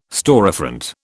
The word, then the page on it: storefront: British English